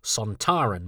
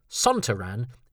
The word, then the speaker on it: Sontaran: but Sontaran is a frequent and unsurprising error from some. In fact, the director of their first story, Alan Bromley, favoured that pronunciation, but the actor playing the character of Lynx, Kevin Lindsay, said quite fairly that as he was from there, he probably knew how best to pronounce it.